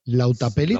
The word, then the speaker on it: Lautapelit: es el segundo juego creo que edita la editorial finlandesa Lautipelit, me parece que se llama, si lo digo bien, y si se me carga la página. Lautapelit. – Lautapelit. Sí, se pronuncia así es de cuatro, de cuatro autores nórdicos y lo vamos a dejar así.